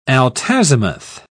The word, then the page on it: altazimuth: British English